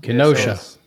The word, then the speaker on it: Kenosha: What is that pronounced? Kenosha, Wisconsin. – Kenosha.